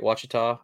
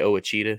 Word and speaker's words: Ouachita: I almost say that wrong because I make fun of it and say it wrong on purpose. I always say Owachita oh, just to mess with people. Owachita. Oh, and I almost said that right then, but I got it right.